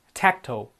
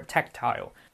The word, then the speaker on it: tactile: 为了使这个文章的中心更加的集中，一些不相关的材料应该被删除。Tangential 不相关的。Number six, tactile or tactile 这个单词有两种读法，读得快一点的话，我们可以把它读成 tactile；或读得完整一点的话，可以变成 tactile。So tactile, tactile means connecting to the sense of touch，触觉的，跟触觉有关的，tactile or tactile。